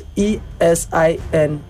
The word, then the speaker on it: Esin: Is E S I N.